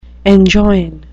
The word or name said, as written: enjoin